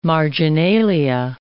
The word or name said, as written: marginalia